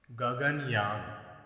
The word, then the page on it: Gaganyaan: Sanskrit; native male speaker